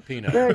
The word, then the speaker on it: Pinot: The T is silent on that one. – Yeah, yeah, Pinot.